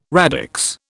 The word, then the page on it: radix: British English